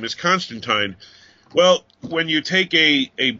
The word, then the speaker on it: Constantine: For those who don't know why we keep stressing teen and time, it goes back to the days of Alan Moore. He created the character, and then at one point he had somebody refer to him as Constantine, and he said Tyne rhymes with wine.